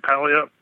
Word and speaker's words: Puyallup: Is that how you say it? Puyallup scream? – Puyallup, yeah.